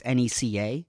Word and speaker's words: NECA: And I noticed Nika, who I believe you're supposed to pronounce N-E-C-A, but I don't want to. – Yeah, it's their fault. – Yeah. – You know? Don't. No one's going to say N-E-C-A.